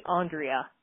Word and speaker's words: Andrea: It's actually Andrea. – Andrea, I apologize.